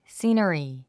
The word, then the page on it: scenery: síːnəri; シーナリィ